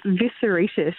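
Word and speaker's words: Vissaritis: It's a good, honest Greek name, buddy. It's Visceritis, or you can say V, whichever one's easier.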